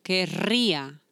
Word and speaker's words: querría: The difference, it's going to be only in the R. That's what's important, the pronunciation. So, quería, it's in the past. Querría, it's in the future.